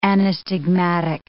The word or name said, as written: anastigmatic